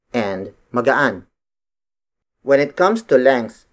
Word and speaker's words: magaan: Magaan. Magaan. Magaan is spelled as M-A-G-A-A-N.